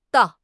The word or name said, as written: dao4